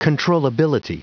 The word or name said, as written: controllability